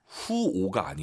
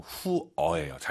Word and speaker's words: 火: 후오가 아니에요, 여러분. 후어예요. 잘 들어보세요.